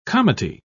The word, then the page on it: comity: British English